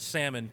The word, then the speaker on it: salmon: Well, here's my other takeaway from the last episode, and that is that I pronounce salmon correctly. – You do. – Okay. – Yeah, Derek does not. Mm-hmm. Derek isn't a follower of the silent L.